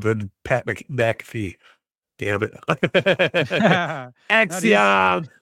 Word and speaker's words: Axiom: Featured Roman Reigns, Seth Rollins, Rhea Ripley, Grayson Waller, Axiom, and Naomi. Look, I got the name right. I could say it now. Axiom, right? That's Axiom.